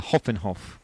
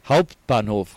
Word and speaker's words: Hauptbahnhof: How do you say it? The Hoffenhof. – Hauptbahnhof.